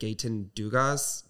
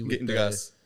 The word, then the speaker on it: Gaëtan Dugas: His name was, I'm going to butcher it, Gaëtan Dugas, G-A-E. – Gaëtan Dugas, The E with the dots above it, T-A-N Dugas, D-U-G-A-S, in case you're interested in researching.